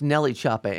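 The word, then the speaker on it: NLE Choppa: It's Nelly Choppa. N-L-E is pronounced Nelly.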